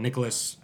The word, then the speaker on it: Nicholas: Nicholas, I don't know if there's any weird way to spell that, but N I C H O L A S.